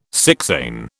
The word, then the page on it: sixain: British English